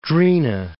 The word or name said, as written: Drina